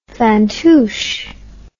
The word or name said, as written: fantoosh